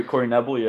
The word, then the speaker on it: Knebel: apparently, they've been in talks with Corey Knebel. Is that how you say his name? Knebel? I don't know. – Corey Knebel, yeah.